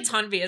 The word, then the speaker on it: Tanvi: I feel like Tanvi is sort of my nickname, since that's not actually how you say my name.